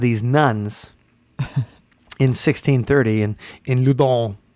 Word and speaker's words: Loudun: Okay, and this is in the mid 1600s in France, actually the 1630s, and so now I don't know if I'm saying it right, like Loudun, Loudun. – Okay, I like that, Loudun.